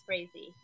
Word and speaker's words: Frazee: So if you want to get a hold of Little Brown by Marla Frazee, did I say that correctly? – Yeah, I did look it up. It is Frazee. Yeah, you're correct.